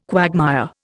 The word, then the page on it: quagmire: Indian